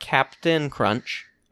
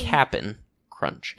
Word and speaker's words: Cap'n Crunch: Not Captain Crunch. I believe you might have been mispronouncing it at some point. – Pardon me. – Cap'n Crunch.